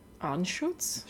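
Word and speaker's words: Anschütz: A-N-S-C-H-U-T-Z. – Anschutz? – Sure. – Spell it again? – A-N-S-C-H-U-T-Z.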